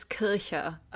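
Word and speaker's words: Kirchherr: Some might name Apple Corps secretary Chris O'Dell, or maybe photographer and friend of the band Astrid Kircher. So sorry if I mispronounced that. I do not speak German. – It's hard. It's Kircher.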